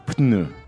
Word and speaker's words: pneu: It's got a P and an N. – Ouais. Donc la prononciation c'est pneu. – Pneu. Okay, so you do have to pronounce them.